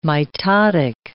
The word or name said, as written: mitotic